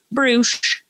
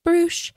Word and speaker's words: Bruush: Brush with two U's, pronounced Bruce. Bruce.